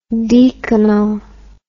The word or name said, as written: deaconal